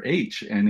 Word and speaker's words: H: they pointed to me for at the letter H, and in German, the letter H is said you, you pronounce it ha.